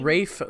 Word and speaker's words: Ralph: It does say Ralph, but it's pronounced Rafe.